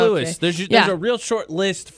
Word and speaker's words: Louis: It's Louis. I just is it Louis? – Here. It says it rhymes with Chewy.